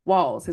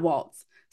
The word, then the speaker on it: Walz: His last name is Walls, not Waltz. So I'm not even gonna hold you. That is the only reason I know how to pronounce and say this man's name correctly, or else I would also say Waltz.